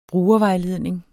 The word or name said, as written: brugervejledning